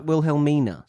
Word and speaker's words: Wilhelmina: Well, it's got an N before the A. – Yeah. – So I'd pronounce it Wilhelmina.